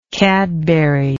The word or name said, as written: cadbury